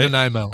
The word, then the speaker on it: Nanaimo: Nanaimo.